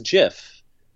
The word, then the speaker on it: GIF: they said it should be pronounced as GIF. They were specific about that from the beginning, and yet that hard G pronunciation, GIF, has really persisted despite the fact that they preferred GIF.